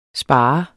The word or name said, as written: spare